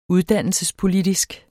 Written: uddannelsespolitisk

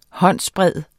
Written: håndsbred

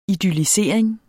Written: idyllisering

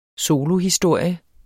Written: solohistorie

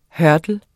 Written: hurdle

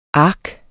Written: ark

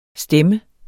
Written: stemme

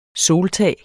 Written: soltag

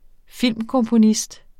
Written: filmkomponist

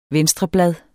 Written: venstreblad

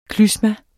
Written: klysma

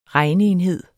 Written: regneenhed

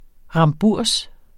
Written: remburs

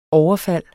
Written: overfald

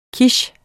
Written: quiche

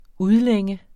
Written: udlænge

